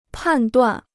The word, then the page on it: 判断: pàn duàn